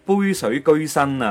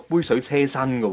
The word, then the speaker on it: 杯水车薪: 而家啲人唔知点解咧，成日将成语入边嘅居咧读成系车嘅，即系例如话应该系。杯水居身啊！喂，而家好多人都系读杯水车薪噶、哦，而汗流浃背咧，系一个读浃背，唔系夹。